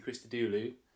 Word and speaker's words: Christodoulou: Daisy Christodoulou. And I have said that right, haven't I? – You have, yes. That's right.